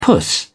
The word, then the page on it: puss: British English